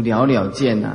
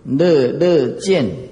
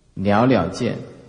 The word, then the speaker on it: 了了见: 是自本以来，它就是摆在那个地方，清净自信就是这样，无所谓迷，更无所谓的物，哎，了了见，无一物，哎，这个读了了见呐，哎呀，你不要别人读错，读成乐乐见，啊，那就麻烦大了啊，了了见。